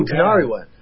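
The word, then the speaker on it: Tinariwen: I got to see Tina Rowan. That's from Mali. – Oh, Tanariwen. – Is that how you say it? – Yeah, Tanariwen. Yeah, that's Tanariwen. Heavy.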